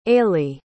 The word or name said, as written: eilidh